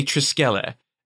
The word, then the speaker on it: Triskele: Oh, something that I liked, by the way, in the stuff with, I'm going to say Triskele and not Triskel. Even if that is the correct pronunciation, because I believe that mine is the more Roman pronunciation, and gosh darn it, I'm going to savor my misbegotten classics education.